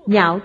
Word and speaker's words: nhạo: nhạo thuyết vô ngại trí. Chữ phải đọc là giàu, nghĩa là ưa thích, yêu chuộng, nhạo thuyết.